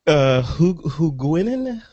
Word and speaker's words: Huguenin: Huguenin? Am I pronouncing that right, Martin? – That sounds good to me.